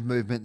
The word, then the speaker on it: MVMT: No, so it's not movement spelt out movement. It's MVMT, an abbreviation of movement that you just pronounce movement.